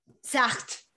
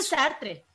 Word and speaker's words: Sartre: Sartre. – ¿no? Porque no se dice Sartre, se dice Sartre.